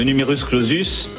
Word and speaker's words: numerus clausus: Le numerus clausus.